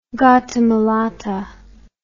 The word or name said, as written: Gattamelata